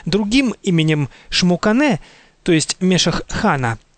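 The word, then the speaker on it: Xmucane: Говоря о сотворении мира, книга Пополь Вух называет прародителей мира именами Шпиякок и Шмукане. Отметим для дальнейшего, что начальная буква «Х» в определенных словах киче и в начале собственных имен произносится как «ше».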